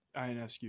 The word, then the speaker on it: Ionescu: I think when I was watching Sabrina Ionescu, that's how you pronounce her last name? – Ionescu, yep.